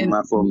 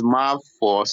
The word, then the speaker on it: Mafo: uh, it's Mafo. So the FO is four, like you're saying. – Four. – four yeah. Mafo.